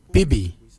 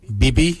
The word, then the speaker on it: bibi: Your bil is spelled B-I-L-Y, bil. The nipple, we say bibi. Bibi is spelled B-I-B-I, bibi, for your nipple.